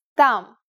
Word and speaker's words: там: It's pronounced там. This is the word for there in Russian.